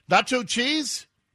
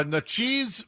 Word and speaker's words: Natchez: Hope I'm saying it right. You Mississippians. Natchez? Natchez? Nacho cheese? Nacho cheese, Mississippi? No, Natchez, Mississippi, which is a historic city.